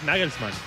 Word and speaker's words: Nagelsmann: Es Nagelsmann.